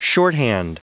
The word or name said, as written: shorthand